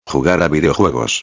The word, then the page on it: jugar a videojuegos: espanhol